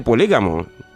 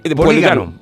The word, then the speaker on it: polígono: Me dijo, dile a Jesús que no se dice polígamo. – No, polígamo no se dice. – Polígono. Polígono se dice.